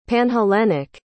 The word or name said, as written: panhellenic